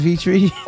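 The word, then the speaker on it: Vietri: It's Matt Vitree. It's M-A-T-T, Matt. My last name is Vitree. V as in Victor, I-E-T-R-I.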